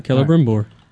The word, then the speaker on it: Celebrimbor: Yeah, Celebrimbor.